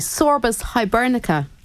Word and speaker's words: Sorbus hibernica: Now, a native white beam tree, Sorbus hibernica, am I saying that right? – You are, yeah.